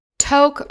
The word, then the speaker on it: Tok: Tok, also spelled like talk, as in TikTok, Alaska, is a small town on the border of Alaska and Yukon, Canada, with a population of around 1,300 people.